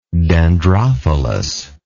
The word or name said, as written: dendrophilous